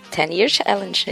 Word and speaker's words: Ten Year Challenge: é assim que pronuncia? Ten Year Challenge, é isso aí.